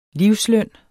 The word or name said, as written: livsløn